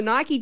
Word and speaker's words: Nike: And Sam said that the Nike chairman, and I say Nike because the Nike chairman, Philip Knight, in 2014, confirmed that it is actually pronounced Nike.